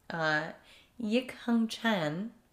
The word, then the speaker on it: Yik Hung Chan: On March 22nd, 1991, there's a 2-year-old victim, Yik Hung Chan, who's also known by Henry. I really hope I said that correctly. – Yes, that sounded good.